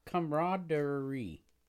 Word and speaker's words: camaraderie: Camaraderie. But it doesn't say what the wrong pronunciation. – Has a silent A that trips people up.